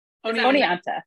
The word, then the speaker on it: Oneonta: She's the head coach for the softball program at, hopefully I'm saying this right, SUNY Juanita? – Oneonta. – I'm sorry. – It is a hard one to pronounce.